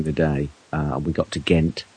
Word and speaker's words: Ghent: That's G-E-N-T, not G-H-E-N-T, as most people spell it in the English-speaking world, probably because if they saw the word spelt G-E-N-T, they would say Gent. – Gent. – And Gant. – It is actually pronounced Ghent, of course.